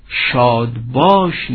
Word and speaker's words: شادباش: این واژه رو شاباش یا شادباش نیز گفتند و هر دو تلفظ و روایت آن نیز بی اشکال است.